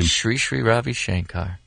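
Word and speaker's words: Sri Sri Ravi Shankar: How do you say his name? – Sri Ravi Shankar.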